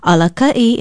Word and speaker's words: Alaka'i: Alaka'i is spelled A-L-A-K-A-Okina'i.